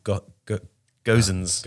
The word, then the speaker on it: Gosens: And Gerson's is Gosens. – Gosens.